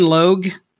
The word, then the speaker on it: Logue: Sean Ann Logue is the TikTok user, and it's spelled S-E-A-N, Sean, and A-N-N, Logue, L-O-U, nope, L-O-G-U-E.